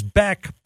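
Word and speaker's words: Bäck: Spelled differently, but the pronunciation is Beck, but could be changed, just like Heiskanen Heiskanen was, just like Bixel and Bischel was, and it's just one of those things where to be determined. But for now, it's Oscar Beck.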